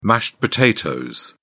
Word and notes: mashed potatoes: British English

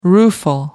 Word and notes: rueful: American English

